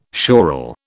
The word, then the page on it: sural: British English